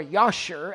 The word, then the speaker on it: Jasher: We have a copy of it in 2 Samuel, but there's also a copy in the book Jasher, or Yashur, as I've heard it pronounced on occasions.